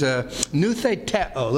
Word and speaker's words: noutheteo: The Greek word is noutheteo. Looks like this: N-O-U-T-H-E-T-E-O. Noutheteo. N-O-U-T-H-E-T. I think it's just E-O. Yeah. E-T-O, just E-T-O, yeah, okay, long O, noutheteo.